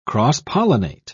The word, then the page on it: cross-pollinate: British English